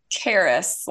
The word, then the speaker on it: Karis: So it's Karis, like Paris, but like so your emphasis is in the first part, Karis.